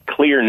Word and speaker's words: Xlear: The Clear nasal spray, and it's spelled X-L-E-A-R, but it clears your nose.